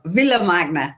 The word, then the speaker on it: Villa Magna: And I want to be sure I'm saying this correctly, as I would say it if I was in Spain. So you help me out. I would say Villa Magna. – It is ideal. Villa Magna.